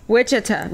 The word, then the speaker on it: Wichita: More specifically, Wichita. I think that's how you say it. Wichita, Kansas. – Wichita. – Oh, my God. – Tell us how to pronounce it. – Wichita.